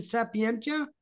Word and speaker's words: Sapientia: I hope I pronounced the name of your show correctly, Sapientia. – Yes.